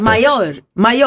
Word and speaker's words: Mallorca: Varför säger man Mallorca? För man ska ju inte uttala L. Man säger inte Pella, man säger Paella. Så jag ska säga Mallorca.